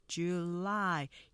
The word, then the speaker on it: July: 두 번째 보면 우리가 주 줄라이 3음절에 스타카토로 하겠지만 음절 보시면 July 이 음절로 하시면서 뒤에 액센트 강조되기 때문에 July 약 강으로 뒤에가 커지죠.